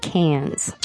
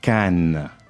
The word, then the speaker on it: Cannes: A lot of times we pronounce it Cannes. – You don't say that. – How do you say it in French? – Cannes. – Cannes. – That's right. And you say Cannes because you don't pronounce the S at the end.